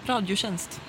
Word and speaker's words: Radio Tjänst: Another thing about Sweden, I received something in the mail recently from Radio Tjänst. – Mm-hmm. – How's my pronunciation on that? – Yeah, Radio Tjänst.